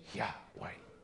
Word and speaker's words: Yahweh: The word in the Hebrew is Y-H-W-H, Yahweh. Almost inconceivable it is to realize that one of the men who stood with Abraham and talked with him face to face was El Shaddai, Yahweh, Almighty God.